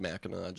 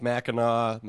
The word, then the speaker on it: Mackinac: Just for anybody listening who's not from Michigan, it's pronounced Mackinac. Just for the record, it's Mackinac, Mackinac Island, Mackinac Bridge.